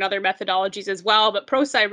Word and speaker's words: Prosci: It is pronounced PROSCI, not PROSCI, which I made a mistake on.